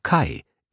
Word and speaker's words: Kaj: Sie wusste nur, dass er vor kurzem aus New York hergezogen war und dass sein Vorname zwar K-A-J geschrieben, aber Kai, wie Mai, ausgesprochen wurde.